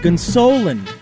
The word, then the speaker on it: Gonsolin: The correct pronunciation of the last name is actually Gonsolin.